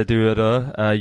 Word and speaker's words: Duodo: Yeah, and if you any listeners out there, I do encourage you to Wikipedia Villa Duodo. You spell it, is it D-U-O-D-O? I think it's spelled, D-U-O-D-O.